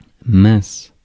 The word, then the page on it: mince: [mɛ̃s]; France